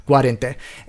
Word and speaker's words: Guarente: In fact, David Sinclair worked in the Guarente, so I don't know if I'm mispronouncing his last name, but it's like, it looks almost like guarantee, but it's Guarente.